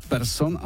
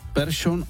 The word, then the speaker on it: Persson: V případě výslovnosti sikavek převážila severská verze změkčení po písmenu R. Tedy jména jako Persson a Larsson, teď to říkám, jak jsme to říkávali kdysi, už dnes ve Švédsku nikdo nevyslovuje ani v oficiálním projevu jinak než Persson a Larsson.